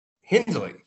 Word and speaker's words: Hindley: Or is Jai – is it Hindy? No, I said it right. Hindley, Jai Hindley.